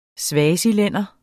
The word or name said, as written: swazilænder